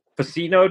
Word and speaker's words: Vicino: We have the honor of having Anthony Vicino. Did I say it right? Probably not. – You nailed it. Yeah, you did it in one.